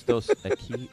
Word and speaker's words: Dos Equis: No, it's dos equis.